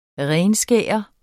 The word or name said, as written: renskære